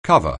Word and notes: cover: anglais

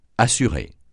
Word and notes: assurer: francais

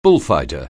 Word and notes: bullfighter: anglais

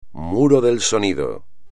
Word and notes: muro del sonido: espagnol

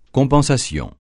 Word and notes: compensation: francais

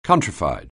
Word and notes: countrified: anglais

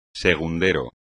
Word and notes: segundero: espagnol

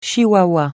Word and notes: chihuahua: français

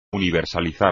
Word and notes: universalizar: espagnol

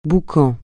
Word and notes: boucan: francais

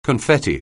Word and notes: confetti: anglais

